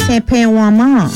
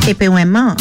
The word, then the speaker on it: tenpewanman: Your bonus Creole word for today is the word Tepewama. And it is spelled TENPEWANMAN. That is TENPEWANMAN. Tepewama.